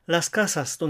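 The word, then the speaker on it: las casas: 複数形にするとラスカサスとなりますから、音節はやはり 3つだし、そのうち 2 つ目の音節にアクセントがあるから弱強弱というパターンであるのはラカサとラスカサス全く同じですね。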